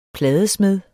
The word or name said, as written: pladesmed